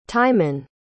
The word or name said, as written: tymon